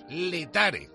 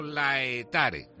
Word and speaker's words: Laetare: y se dice Laetare, no Laetare.